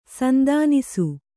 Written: ಸಂದಾನಿಸು

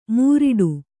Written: ಮೂರಿಡು

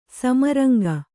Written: ಸಮರಂಗ

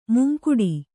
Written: ಮುಂಗಡೆ

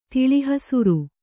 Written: ತಿಳಿಹಸುರು

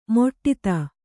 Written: ಮಡದಿ

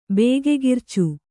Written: ಬೇಗೆಗಿರ್ಚು

